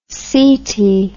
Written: seti

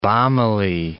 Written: balmily

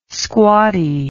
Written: squatty

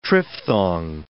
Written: triphthong

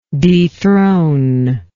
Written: dethroner

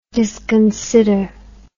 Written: disconsideration

